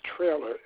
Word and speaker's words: Traylor: Henry, H-E-N-R-Y. – Henry. – And my last name is Traylor. It's spelled T-R-A-Y-L-O-R.